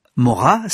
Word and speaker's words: Morat: Mora s'écrit M-O-R-A-T.